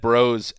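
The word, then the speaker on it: Breaux: Rap Sheet reported that the fracture was discovered when an independent doctor read Bro's x-ray.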